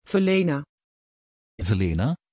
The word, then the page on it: vilena: Dutch